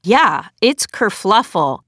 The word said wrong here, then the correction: kerfuffle: and it's actually kerfuffle. There isn't an L, but But I've literally been saying it wrong for, I guess, my entire life.